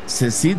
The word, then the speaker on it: CSIDH: Cecid se escribe C-S-I-D-H. Aquí lo estás viendo abajito de mí.